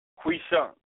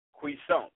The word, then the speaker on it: quiescent: How do you pronounce this? Cuisant. Cuisant. Q-U-I-E-S-C-E-N-T.